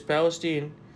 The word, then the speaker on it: Palestine: It's Palestine? – It is Palestine. Because we found out this morning that Buttigieg said it wrong.